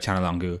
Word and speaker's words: Çalhanoğlu: hey, it's pronounced Çalhanoğlu. And they're like, what? And he's like, listen, repeat after me. Chan. And they're like, Chan. Longu. Longu. Çalhanoğlu.